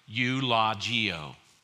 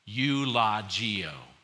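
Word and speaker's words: eulogeō: And it's pronounced maybe a little bit differently. Eulogio. Say it with me. Eulogio. That's the Greek word.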